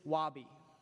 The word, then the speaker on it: wabi: The first is wabi. Rhymes with Bobby. It is Japanese origin.